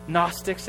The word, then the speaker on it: Gnostics: Gnostics, it comes from a Greek word meaning, yeah, like knowledge. I heard somebody say knowledge, kind of a hidden knowledge, a mystic kind of knowledge. And Gnostics